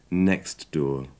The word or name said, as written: next door